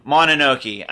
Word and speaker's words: Mononoke: Yeah, Princess Mononoke is number 70. – Mononoke.